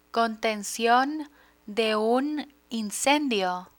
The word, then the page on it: contencion de un incendio: Spanish